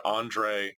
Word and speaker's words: Andree: It's a real short book, just a collection of short essays by a female author named Andre Sue. And that's spelled A-N-D-R-E-E. Andre Sue is spelled S-E-U.